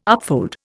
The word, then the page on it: upfold: Indian